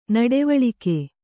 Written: ನುಡಿವಳಿಕೆ

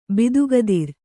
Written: ಬಿದುಗದಿರ್

